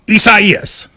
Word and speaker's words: Isaias: A lot of people, you know, prayers to everybody involved, but it is Isaias. – Is that how you say it, though? – You do.